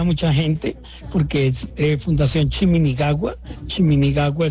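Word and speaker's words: Chiminigagua: Él hace parte y es el director, uno de los directores de la Fundación Chimini Nagua. No, ya lo dije mal otra vez, pero voy a ensayarlo aquí, Chimini. Chimini Gagua. Ahora sí lo dije bien.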